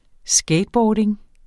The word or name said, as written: skateboarding